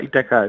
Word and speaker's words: Ithaca: Äh, Ithaca. – Ithaka. Aber Ithaca ist auch okay. so wie in der Odyssee.